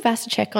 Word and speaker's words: Vasicek: Well, I'm Rachel Vasicek on Instagram, which is a bit of a mouthful.